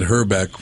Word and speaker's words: Hrbek: An example, Billy, would be Kent Herbeck from the Minnesota Twins, won a couple of World Series. His name is spelled H-R-B-E-K. Bohemian guys, just you get one vowel, that's it.